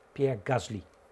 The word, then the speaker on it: Pierre Gasly: Is, ik zal het goed uitspreken, dames en heren, Pierre Gasly.